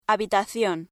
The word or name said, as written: habitación